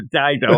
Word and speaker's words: Dido: Dido, which I was asking you how to pronounce, and you're like, it's like the singer Dido, and we both giggled.